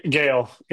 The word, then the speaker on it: Gehle: G-E-H-L-E. How do you pronounce that? – Gale, yeah. – Oh, Seth Gale. – Yes, sir. – I would have pronounced it Gailey. Okay, so it's Seth Gale.